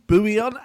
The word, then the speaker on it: bouillon: It's just like, yeah, I suppose it's just water with bouillon. That's it. You're laughing. – Man, I'm so glad that everyone's loving bouillon.